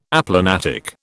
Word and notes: aplanatic: British English

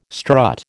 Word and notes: strut: American English

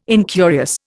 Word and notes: incurious: Indian

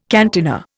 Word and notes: cantina: Indian

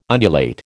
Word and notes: undulate: American English

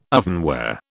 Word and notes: ovenware: British English

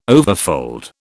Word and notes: overfold: British English

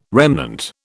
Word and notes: remnant: British English

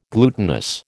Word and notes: glutinous: American English

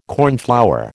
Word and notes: cornflower: American English

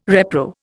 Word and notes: repro: Indian

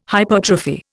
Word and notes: hypertrophy: Indian

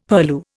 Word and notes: purlieu: Indian

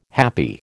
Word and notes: happy: American English

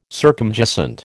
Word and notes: circumjacent: American English

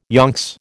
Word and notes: yonks: American English

